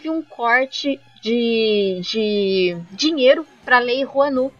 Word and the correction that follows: Rouanet: é Rouanet ou Juanu? Eu tô falando certo? Rouanet. – Rouanet, é, certo? Isso.